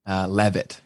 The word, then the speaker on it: Levitt: Yeah, and honestly, like I can't even get mad at people for pronouncing it Leave It because it is spelt like that, but it is pronounced Levitt.